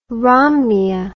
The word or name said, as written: romneya